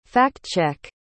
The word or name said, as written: fact-check